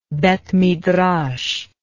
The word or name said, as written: beth midrash